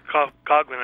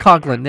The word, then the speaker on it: Coughlin: Just to explain a little bit, you mentioned his background growing up in Boston, anti-Semitic area, Father Coughlin, am I saying that correctly? – Father Coughlin, I think. – Coughlin, yeah, I knew.